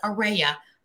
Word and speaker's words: Aurea: Many people can't pronounce my name when they see it. They're like, Aurora, you know, they don't know how to pronounce it. And I say it's pronounced Aurea, like Aurea Hope.